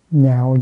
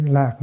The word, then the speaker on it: nhạo: Nhạo dục kiến sa môn, đây là chữ lạc nữa, mình đọc là chữ nhạo.